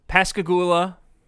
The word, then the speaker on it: Pascagoula: So in 1973, Pascagoula, I think that's the correct way to pronounce it, Mississippi. – Yeah.